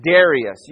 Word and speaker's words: Darius: And then there's a king called Darius. You might pronounce it Darius.